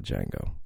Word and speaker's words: Django: You can't have the Django. The D is silent.